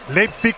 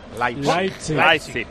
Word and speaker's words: Leipzig: Va a jugar el Leipzig o Leipzig, ¿cómo se dice, Pedro Martín? – Leipzig, como se escribe. – Muy bien, el otro día oí a un moderno que lo decía de otra manera. – Leipzig.